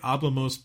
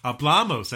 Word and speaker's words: hablamos: And I'll say this wrong. I'll say hablamos pronto. – Hablamos. – Hablamos. That's right. Hablamos. – It's where you put the accent.